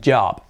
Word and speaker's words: job: Luego la O que es ese sonido, job. Y acabado en B. Job. Job. No job, es job. Casi es una A, casi.